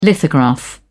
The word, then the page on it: lithograph: British English